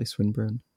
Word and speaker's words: Swinburne: Am I pronouncing that correctly, Swinburne? – Yeah.